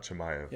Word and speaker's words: Chimaev: Kuzmat right? Chimaev. – Yeah, Chimaev.